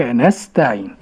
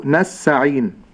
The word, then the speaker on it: نستعين: وإياك نس، ولكن النطق الصحيح وإياك نستعين. – نعم، هكذا. وأحترز ألا تغيب التاء، فبعض التالين لكتاب الله يقول نسعين. – نعم. – من غير تاء، لأنه لا يلفظ بالتاء أو لا يرتفع اللسان إلى مخرج التاء.